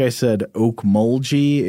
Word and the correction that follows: Okmulgee: It's apparently Okmulgee with a hard G.